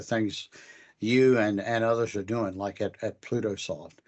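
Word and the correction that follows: PlutoShift: Pluto Shift. I said it wrong.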